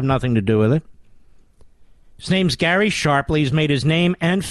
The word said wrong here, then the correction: Shapley: Sharp, Shapely, rather, is his name. Which is it, Mister Bidus? We have both. I think it's I think it's Shapely. S H A P L E Y.